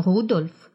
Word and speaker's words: Рудольф: Заметьте, имя Рудольф произносится с ударением на первом слоге.